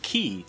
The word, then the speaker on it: chi: X is the Greek letter for chi, but pronounced key.